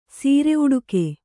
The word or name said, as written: ಸೀರೆಉಡುಕೆ